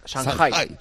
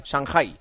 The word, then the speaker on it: Shanghai: Y luego ya ¿cómo se pronuncia? – Shanghai. Shanghai. Perfecto, pues sí.